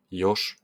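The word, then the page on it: jos: Finnish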